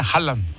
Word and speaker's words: Haaland: Ya me están corrigiendo aquí, el futbolista es Haaland, se pronuncia Haaland. Haaland, el noruego.